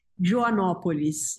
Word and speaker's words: Joanópolis: Help me with the pronunciation of the town. Is it Joanópolis? – Joanópolis.